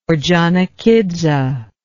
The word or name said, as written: ordzhonikidze